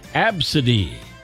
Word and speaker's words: Abcde: The name ABCDE, pronounced Absidy.